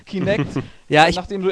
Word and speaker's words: Kinect: Unter anderem natürlich das ehemalige Projekt Natal, was jetzt Kinetic heißt. – Falsch, nee. du hast das schon falsch nee. gesagt. – No. Das habe ich falsch gesagt. Kintech. Kintech. – Kinect. Kinect, verdammt. Kinect.